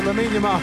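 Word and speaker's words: Lamine Yamal: If I said Lamin Yamal, would that sound right to you? – Yeah, that sounds right. – There we go, Lamin Yamal. That's correct.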